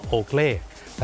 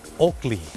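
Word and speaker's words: Oakley: คนไทยออกเสียงว่าโอเกลแต่เจ้าของภาษาไม่ได้ออกเสียงว่าโอเกลครับเขาออกเสียงว่าโอกลีโอกลี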